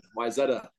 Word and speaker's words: Wayzata: Wayzata is, a lot of people say Wayzata, but that's good. Wayzata, that's the way to go.